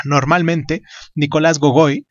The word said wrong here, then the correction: Gogol: Gogol, perdón, eh,